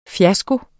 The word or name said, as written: FIASKOERNES